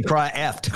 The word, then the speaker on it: Kreeft: Why did you say his name? – It's actually Kreeft.